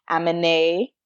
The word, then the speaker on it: Aminé: My first song is Caroline by Aminé. – Yes, accent on the E, like Beyonce.